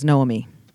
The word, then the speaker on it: Noemi: It's Noemi. – Noemi. – Not all one, like separate syllables. Noami.